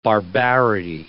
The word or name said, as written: barbarity